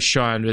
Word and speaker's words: Sian: It's Sian or Sean. Probably Sean. It's spelled S-I-A-N.